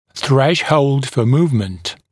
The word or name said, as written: threshold for movement